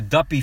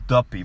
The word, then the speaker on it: Duppy: The song, the Duppy freestyle. – Duppy. – We gotta go. Duppy. You gotta say it right. It's not Dup. It's Duppy, bro.